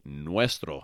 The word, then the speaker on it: nuestro: And if it's masculine, it is nuestro. And that is spelled N as in Nancy, U-E-S-T-R-O, nuestro.